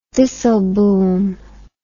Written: disselboom

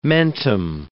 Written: mentum

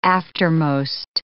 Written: aftermost

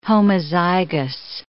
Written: homozygous

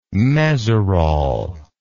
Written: mesurol

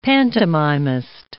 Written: pantomimist

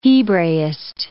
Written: Hebraist

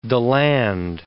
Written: Deland

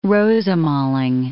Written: rosemaling

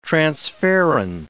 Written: transferrin

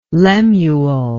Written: lemuel

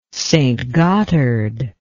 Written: St Gotthard

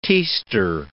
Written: tester

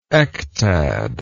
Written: ectad

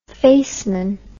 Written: faceman

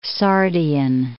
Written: Sardian

